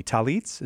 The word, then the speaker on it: tallit: What's the teaching on Catholics owning Jewish you might have to help me, tallits, is that how that's pronounced? – Yeah.